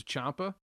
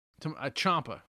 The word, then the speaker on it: Ciampa: We got Gargano versus Ciampa. Am I saying his name right? – Yeah. Tommaso Ciampa. – Ciampa.